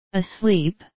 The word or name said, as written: asleep